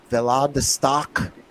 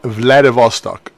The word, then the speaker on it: Vladivostok: And that the Trans-Siberian Railway, this would be a great one for a gothic adventure, travels between Russian cities of Moscow and, I'm going to say this wrong, Vladivostok, passes 10 distinct time zones over a 6-day journey. – It's Vladivostok.